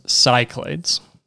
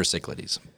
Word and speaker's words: Cyclades: So the next one, and this one came at a crashing collision over our board game weekend, and that is how I fondly pronounce Cyclades. – Cyclades. – Is probably the actual. I call it Cyclades, and I've kind of like stuck with that at this point. – It's pretty sure Cyclades.